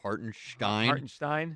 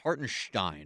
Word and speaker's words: Hartenstein: You mean like they want to pronounce the name correctly? – You want less enunciation? – Yeah, would you just lay off the sh part? – for a little bit. So Hartenstein.